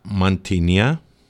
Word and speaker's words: Mantinia: Moscho Filero, it's Mantinia, Agio Rigitico, Nemea, Assyrtico, Santorini.